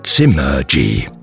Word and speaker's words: Zimmer-G: It contains a word in German and a letter that we, for some irrational reasons, habitually pronounce as an English one. It sounds like this, Zimmerge, and that's how it's spelled, Zimmer-G.